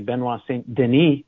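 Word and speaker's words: Benoît Saint-Denis: It's Benoit Saint-Denis, a minus 225. Actually, I assume that's actually Benoit Saint-Denis, if we're going with the French pronunciation.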